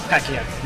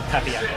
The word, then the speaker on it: Pacquiao: Pacquiao, not Papio.